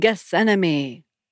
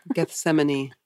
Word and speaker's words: Gethsemane: How dare you feel any pain or anxiety about missionary service when Jesus suffered for you on the cross and in Gethsemane? – Gethsemane.